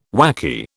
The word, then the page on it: whacky: British English